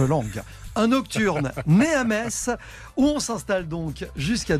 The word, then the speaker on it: Metz: Voilà, il y a eu des problèmes parce qu'on dit Metz tout bêtement, même si ça s'écrit M E T Z.